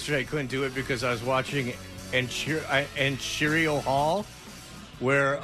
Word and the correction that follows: Arsenio: Arsenio is how you pronounce it.